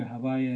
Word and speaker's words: Havaya: Io dico Yud-Hei, Yud-Hei-Vav-Hei, le quattro lettere, ma il nome pronuncio come Havaya. In un altro ordine. Il nome Havaya è anche una possibilità?